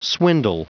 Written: swindle

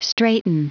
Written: straiten

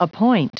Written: appoint

